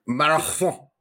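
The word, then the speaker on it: Marathón: Marathon. – Club Deportivo Marathon of Honduras. Ah. – Marathon. – Oh, yeah, because of the silent H's.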